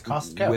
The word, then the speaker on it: Costco: I like that you pronounced it correctly. – Oh. Yeah, it's Costco. – With the T.